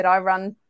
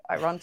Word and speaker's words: Irun: And she was like, I run to Bilbao. And I thought, I probably haven't got the pronunciation right for that. But it is, yeah. – Irun. – I know. Irun.